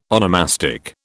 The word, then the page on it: onomastic: British English